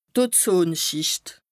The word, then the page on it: Ozonschìcht: Bas Rhin